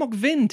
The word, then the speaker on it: Ok-bin: The actors are Kim Ok-bin, or Kim Ok-vin, technically, sometimes. It depends on pronunciation.